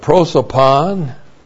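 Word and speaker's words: Prosopon (πρόσωπον): And then we have prosopon. That's P-R-O-S, long O-P-O-N.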